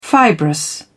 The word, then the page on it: fibrous: British English